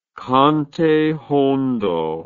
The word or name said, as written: cante hondo